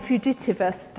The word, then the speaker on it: fugitivus: Sometimes they were in prison, other times they were branded with the letter F, which is the Latin fugitivus, that's how you say it, which is the Latin for fugitive or runaway.